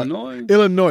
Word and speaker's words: Illinois: Illinois, because there's an S at the end, but then the S is silent.